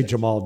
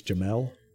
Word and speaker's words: Jamel: I say Jamal Dean all the time, right? It's Jamel.